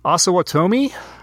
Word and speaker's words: Osawatomie: I love the local history of the Santa Fe Trail and the Civil War and have been down to Ossel. Oof, should have looked up how to pronounce this city. Osawatomie.